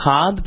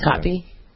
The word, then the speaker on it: Kotb: I think it was always mispronounced Cobb, but yeah, it's Kotb.